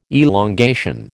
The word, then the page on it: elongation: American English